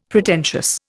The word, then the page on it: Pretentious: Indian